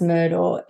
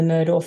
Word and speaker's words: Murdaugh: This is one of the most Googled terms over the weekend the Murdaugh family. It's spelled M U R D A U G H. So it sounds a bit like murder or Murdoch, but it's actually that's spelt murder.